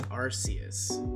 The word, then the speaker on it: Arceus: It might be Arceus. – How to pronounce the god? – No, wait, it is Arceus.